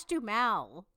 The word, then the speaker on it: Duhamel: Josh Duhamel. – Oh, is that how you say it? Duhamel? Is that how you say it? – Yeah.